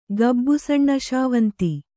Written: ಗಬ್ಬುಸಣ್ಣಶಾವಂತಿ

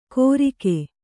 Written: ಗೊರಿಕೆ

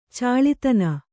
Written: ಚಾಳಿತನ

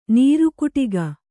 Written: ನೀರುಕುಟಿಗ

